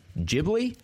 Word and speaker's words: Ghibli: Ghibli. Did I say it right? – Yeah. Yeah. So when we looked it up, I accept either way, the Ghibli or Ghibli.